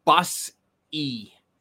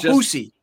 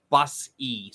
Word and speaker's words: Bussi: And I want to Brandon Bussi's father DM me and said everybody's got his name wrong. it's Bus E. – It's not Boosie. – No, it's not Boosie. It's Bus E.